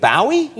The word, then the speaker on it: Bowie: You say Bowie, but Bowie. David Bowie has it. – In the United Kingdom, you say Bowie? – Bowie. – David Bowie? – Yeah, you don't like it, do you?